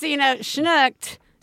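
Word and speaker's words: snyggt: Yeah, just, schnooked.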